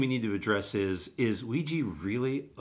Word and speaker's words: Ouija: The number one movie a couple of weeks back was Ouija, or Ouija, however we want to pronounce it. – Ouija.